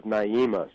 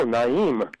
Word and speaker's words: Naim: It's funny, jazz people see that, and it's the first four letters of Naima, so they just sort of naturally go, oh, Naim. But it's actually just pronounced Naim.